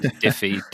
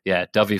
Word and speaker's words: Dyfi: So the mispronunciation comes from the fact that we went with the Welsh spelling, D-Y-F-I, rather than the English spelling of Dovey, because we thought we'd be respectful to our Welsh neighbours. So, but sadly, we've had Diffy, Diffy, and various other mispronunciations. So Dovey Falls.